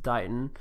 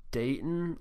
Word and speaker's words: Dighton: I believe it's pronounced Dighton. I think I pronounced it Dayton last time, so it's Dighton, like D-I-G-H-E-N, but it's spelled like bright with a D and then on.